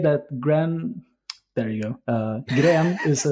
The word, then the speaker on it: Gren: It's a Norwegian word. So I'm thinking that if I'm not pronouncing it correctly, then it doesn't stand a chance. Gren is fine.